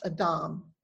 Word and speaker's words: Adam: The word Adam is pronounced Adam in Hebrew, and it is just the Hebrew word for human.